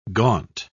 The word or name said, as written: gaunt